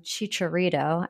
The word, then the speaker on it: Chicharito: And then my second is I saw this quote about the LA Galaxy from Chicharito.